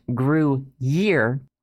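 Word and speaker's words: Gruyère: Ah, I love Looks like it's pronounced Gruyere, not the case.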